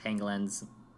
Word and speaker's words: pangolin: such like pangolins. Yeah, it's an animal known as a pangolin. – Yeah, you gotta be careful pronouncing that one. It really sounds like penguin.